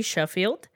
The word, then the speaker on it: Scofield: Sheffield. Last name or first name B.E. Last name S-C-O-F-I-E-L-D, but appears to be pronounced Sheffield.